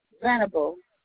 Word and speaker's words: Venable: Marie Venable. – Can you spell that last name for me? – V as in Victor, E-N-A-B-L-E.